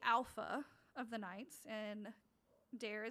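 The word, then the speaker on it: Daire: But it's D-A-I-R-E. – Dare. I would have pronounced that initially as Dairy. – That's funny. I probably would have too if she hadn't said... She, like, says it in the, the reading, in the text, that it rhymes with care or something.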